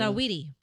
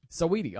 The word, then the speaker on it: Saweetie: How do you pronounce it, though? – Saweetie. – Saweetie. Okay, so I was right.